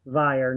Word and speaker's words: Vyer: Okay, first, Kara, it's Vyer, not there. It rhymes with liar, although I'm not one.